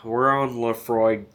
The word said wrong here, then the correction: Laphroaig: John, did you find out if you pronounce the G? – You do pronounce the G. I like it. I like a hard G, you know? Um, so yeah, Laphroaig 10.